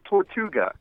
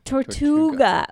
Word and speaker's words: Tortuga: And the show is called Men of Tortuga. Did I say it right? – Tortuga. – Tortuga.